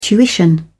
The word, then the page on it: tuition: British English